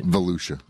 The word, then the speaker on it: Volusia: I'm not sure if that's the proper way to pronounce it. – Volusia. – Volusia, not Volusia. Volusia.